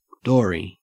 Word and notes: dory: Australian English

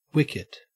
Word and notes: wicket: Australian English

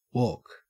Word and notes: walk: Australian English

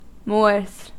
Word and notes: moors: American English

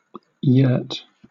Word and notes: yurt: British English

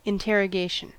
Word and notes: interrogation: American English

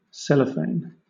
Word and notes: cellophane: British English

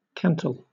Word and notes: cantle: British English

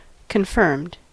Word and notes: confirmed: American English

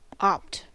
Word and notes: opt: American English

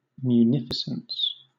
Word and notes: munificence: British English

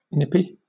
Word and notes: nippy: British English

